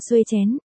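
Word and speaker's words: Xuechen: Tuyết Thần phát âm là xuê chén, đảo ngược lại tức chén xuê, đồng âm với Trần Tuyết.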